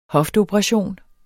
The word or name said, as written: hofteoperation